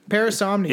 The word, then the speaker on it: parasomnia: Like, I don't know the right way, parasomnia. – Is the P silent? – No, I'm telling you, that's how you say it.